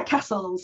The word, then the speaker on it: Cassels: Yeah, Cassels. Yes. I like the building, but not spelled the same.